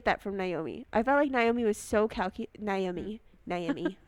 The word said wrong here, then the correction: Noemi: I say Naomi. – Oh God, we're gonna pronounce her name so wrong. This whole Noemi.